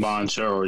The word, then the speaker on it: Banchero: They drafted, excuse me, I might be butchering these names, Paolo Banchero from the Duke Blue Devils. – Banchero, yes.